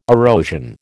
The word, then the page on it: erosion: American English